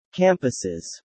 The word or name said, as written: campuses